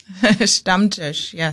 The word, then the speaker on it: Stammtisch: And, well, isn't there, I was reading in the thing that you sent me, there's a German word, and I'm going to say it wrong, Stammtisch? – Stammtisch, yes. – Oh, I was close.